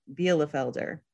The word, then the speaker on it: Bielefelder: Our next new Meyer Hatchery breed for 2022 is the Bielefelder. Sounds a little German.